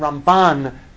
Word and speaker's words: Ramban: So the way to remember it is we say Rambam, Maimonides, with the emphasis on the first syllable, and Ramban, with Nachmanides, with the emphasis on the second syllable.